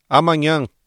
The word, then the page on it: amanhã: ブラジルポルトガル語